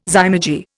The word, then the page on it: zymurgy: Indian